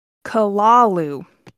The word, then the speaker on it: Cthulhu: Here's every way I have gotten. The Cthulhu. – Mm-hmm. – The Cthulhu. Mm-hmm. But according to the internet, it says the Kalalu. – What? – What? – That's Cthulhu.